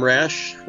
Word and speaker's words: Rasch: Uh, I am Tom Rasch, last name is spelled R-A-S-C-H, calling from Rapid City, South Dakota.